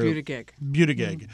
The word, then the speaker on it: Buttigieg: The mayor Buttigieg.